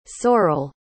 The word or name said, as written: Sorel